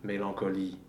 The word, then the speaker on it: mélancolie: But the word can also end with a vowel and then a schwa. And in the case, you just pronounce that vowel before the schwa, mélancolie.